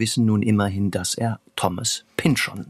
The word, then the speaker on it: Thomas Pynchon: Also man sagt Thomas Pynchon.